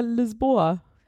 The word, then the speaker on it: Lisboa: Wie spricht man das denn auf Portugiesisch aus? – Ja, da heißt es ja Lisboa.